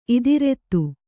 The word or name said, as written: ಇದಿರೆತ್ತು